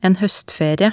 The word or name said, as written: en hoestferie